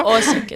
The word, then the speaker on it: Osijek: Pronúncialo bien, porque lo he pronunciado mal, seguro. – Osijek, está bien.